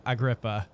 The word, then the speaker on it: Agrippa: Uh Darren, with your uh measly one as you yank the the coat back from uh Agrippa I, I'm saying that right, correct? Yeah, that's right.